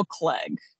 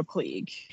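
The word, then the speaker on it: Cleage: I think it's Pearl Clegg, not Pearl Klieg. – Oh, yeah. I've definitely said Klieg. – Yeah. So when I was writing up the bio, I realized that it said pronounced Clegg.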